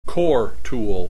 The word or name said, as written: core tool